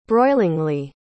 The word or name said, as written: broilingly